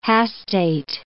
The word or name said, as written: hastate